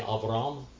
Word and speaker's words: Avram: Abram. Avram. Can you say Avram? That's how you say it in Hebrew, Avram.